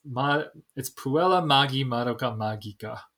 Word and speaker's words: Puella Magi Madoka Magica: I mispronounced that when I said it the other time, actually. It's Puella Magi Madoka Magica. There's no J sound. It's all G, hard G's all across the board.